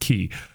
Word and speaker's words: Quay: But the important thing is there was a place in the River Market called Key Coffee, Q U A Y, uh, pronounced Key.